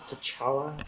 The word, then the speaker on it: T'Challa: Is that how it's pronounced, T'Challa? – I believe so.